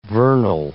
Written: vernal

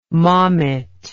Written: maumet

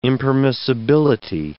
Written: impermissibility

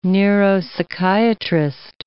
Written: neuropsychiatrist

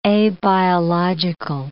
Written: abiological

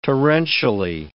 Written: torrentially

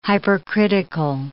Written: hypercritical

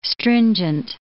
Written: stringent

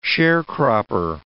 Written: sharecropper

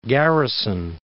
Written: Garrison Dam

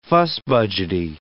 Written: fussbudgety

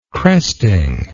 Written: cresting

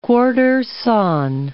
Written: quartersawn

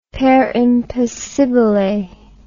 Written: per impossibile

